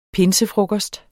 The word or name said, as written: pinsefrokost